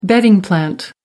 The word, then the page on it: bedding plant: American English